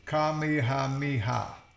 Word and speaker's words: Kamehameha: You know what, Just, you read that, break it down, and say it. – Kamehameha. Right? – I guess. – That's what I said. – It's saying the H is silent in the huh.